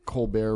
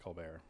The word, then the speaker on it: Colbert: I think that's just how his name is pronounced, Colbert, I think. – I think it's Colbert. – Well, I mean, I know he did that during the writer's strike. He decided to have his name be Colbert.